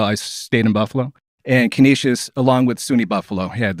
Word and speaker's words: Canisius: You went to, is it pronounced Canisius? – Canisius College, yeah.